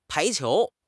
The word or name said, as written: pai2 qiu2